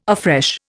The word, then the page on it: afresh: Indian